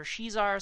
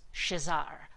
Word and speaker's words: Shazar: Some people call her Shizar, some people call her Shazar. I believe it's pronounced Shazar, like Shazam, but with an AR at the end.